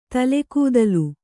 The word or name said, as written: ತಲೆಗೂದಲ್